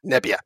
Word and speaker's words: Nebia: Nebia.